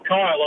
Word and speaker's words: Khyll: But it's spelled with five letters. There's no vowel in it. – What do you mean? – Well, exactly that. – Spell it. – K-H-Y-L-L. – Kyle's got a lot of attitude. – So do people call you Kill? – They do, actually, sometimes.